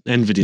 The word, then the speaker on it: NVIDIA: Is it NVIDIA? Or how do you pronounce it normally? NVIDIA's? NVIDIA's. – No, NVIDIA. – Yeah. I think other people just said it differently. I've always like confused by NVIDIA. Their pronunciation, NVIDIA, yeah. So I was right the first time.